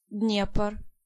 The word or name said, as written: Dniepr